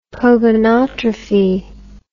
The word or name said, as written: pogonotrophy